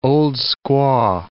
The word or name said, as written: old-squaw